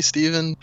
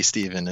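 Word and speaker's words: Stevan: So it's phonetically Stefan, but it's supposed to be Steven is what the intention was.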